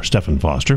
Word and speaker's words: Stephen Foster: Stephen Foster, or Stephen Foster, for those who pronounce it that way.